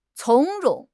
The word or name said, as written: 从容